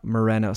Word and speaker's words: Moreno: Moreno, see? – Yeah.